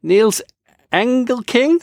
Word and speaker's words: Nils Engelking: Today, we are speaking to Nils Engelking.